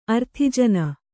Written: ಆರ್ತಜನ

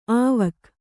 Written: ಆವಕ್

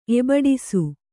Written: ಎಬಡಿಸು

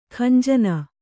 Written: ಖಂಜನ

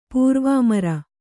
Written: ಪೂರ್ವಾಮರ

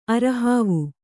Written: ಅರೆಹಾವು

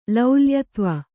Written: ಲೌಲ್ಯತ್ವ

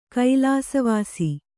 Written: ಕೈಲಾಸವಾಸಿ

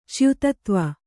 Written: ಚ್ಯುತತ್ವ

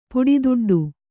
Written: ಪುಡಿದುಡ್ಡು

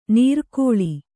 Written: ನಿರ್ಗಾಳಿ

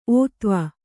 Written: ಓತ್ವ

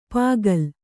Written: ಪುಕ್ಕಲಿ

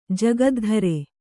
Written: ಜಗದ್ಧರೆ

